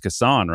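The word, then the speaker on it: Cason: I mean, he's been on a tour saying basically that his name is pronounced Kassan, right? Is it Kassan Wallace? – Oh, he's I'm going by Kassan now.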